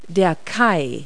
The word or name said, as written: quay